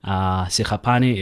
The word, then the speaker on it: Sekgapane: So Levy, the English way, it's L-E-V-Y. Sekgapane is S-E-K-G-A-P-A-N-E. So in my home language, it will be Sekgapane. – So S-E-K-G.